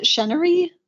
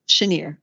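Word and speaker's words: Cheniere: And how do you pronounce it? Chenery? Is that how it's pronounced? Chenier.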